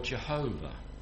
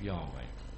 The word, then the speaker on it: Yahweh: When Lord is in capital letters, it means Yahweh, or Jehovah, as we know it, the modern mispronunciation of Yahweh.